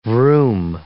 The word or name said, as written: vroom